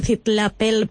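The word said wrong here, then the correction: Citlaltépetl: Pico de Orizaba, algo así, ¿no? ¿No lo he dicho bien o lo he dicho mal, Francisco? – Sí, bueno, Citlatepetl.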